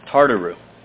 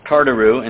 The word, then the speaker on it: Tartaroo: The second Greek word translated hell that we'll look at in the Bible is Tartaru. Tartaru, and it's spelled T-A-R-T-A-R-O-O.